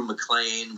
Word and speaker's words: MacLean: My handle is just my name, Andrew McLean, with no punctuation or anything. McLean is spelled M-A-C-L-E-A-N.